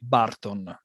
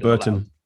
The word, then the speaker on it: Burton: we call it Barton. That's how we call it in Italy. How do I pronounce it? Then I get to the question. How do I pronounce it correctly? I never said it. – Burton. – Burton. Burton. Very different.